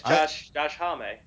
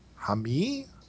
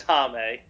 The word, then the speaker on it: Josh Homme: Yeah, it's Josh Homme. – Okay. I had always wondered. I was like, how do you pronounce that? Is it Hami? Home? What is it? – It's Homme.